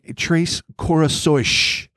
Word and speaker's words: Três Corações: So, Vittorio, a ufologist since his teenage years, grew up in Três Corações, which I've been pronouncing as Três Caracos all fucking series, so live with that, where the ESA base is located.